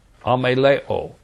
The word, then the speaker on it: ameleo: That Greek word is pronounced ameleo, A-M-E-L-E-O, ameleo.